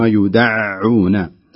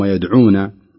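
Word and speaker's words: يدعّون: أيضا في كلمة يوم يدعون إلى نار جهنم دعا، لابد من تحقيق التشديد على العين، يوم يدعون، وأيضا دعا، لا يقول الإنسان أو القارئ يوم يدعون كما يعني يحصل عند بعض الذي يقرأه بالخطأ، لابد أن يشدد العين في نطق هذا الحرف.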